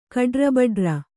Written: ಕಡ್ರಬಡ್ರ

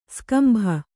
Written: ಸ್ಕಂಭ

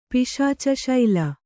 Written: ಪಿಶಾಚಶೈಲ

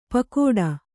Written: ಪಗೋಡ

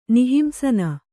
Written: ನಿಹಿಂಸನ

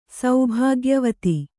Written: ಸೌಭಾಗ್ಯವತಿ

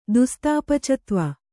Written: ದುಸ್ತಾಪಚತ್ವ

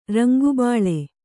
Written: ರಂಗುಬಾಳೆ

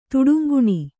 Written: ತುಡುಂಗುಣಿ

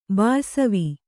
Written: ಬಾಯಿಸವಿ

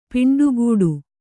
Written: ಪೆಂಟೆಗಟ್ಟು

